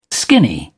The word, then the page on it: skinny: British English